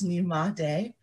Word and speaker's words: Nymade: As she mentioned, my name is Nymade. Nymade Boiwu is the full name. When you first see it, it looks a little intimidating, but it's just three syllables, Nymade.